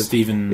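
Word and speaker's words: Stjepan: How do you say it? – Stephen. – Is it just Stephen, even though it looks weird? – Yeah, I think it's just Stephen Sajic.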